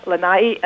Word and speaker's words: Lanai: We'll look forward to hearing more about Wailea, Lani, and Mauke. – No, Stephen, it's Wailea, Lanai, and Molokai.